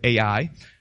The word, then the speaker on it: Ai: They're getting ready to take over Ai, which is we can pronounce as I or Ai.